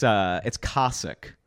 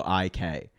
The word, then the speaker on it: Koscuik: Yeah, it's Koscuik, and it's spelled Koscuik.